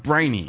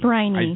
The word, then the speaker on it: Briney: I think it's pronounced Briney. – Briney. – I do believe. – Okay. Yes. Like Briney Shore.